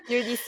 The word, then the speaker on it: Eurydice: I don't know if yeah. – I said it right. – Yeah, Eurydice. Yeah, it's a hard one.